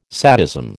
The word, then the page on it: sadism: American English